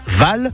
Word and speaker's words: Vasles: Je vous rappelle que c'est dans une commune qui s'appelle Val et dans votre GPS, ça s'écrit V-A-S-L-E-S, tout simplement.